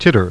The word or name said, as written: titter